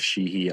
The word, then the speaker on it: Sheehey: So my personal page is Joseph Sheehy on Instagram. Last name is spelled S-H-E-E-H-E-Y.